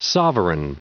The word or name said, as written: sovereign